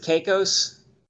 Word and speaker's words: Caicos: Is that how you pronounce it, Caicos? – Turks and Caicos, yeah.